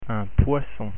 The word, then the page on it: poisson: French